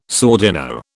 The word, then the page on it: sordino: British English